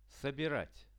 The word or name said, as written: sobirat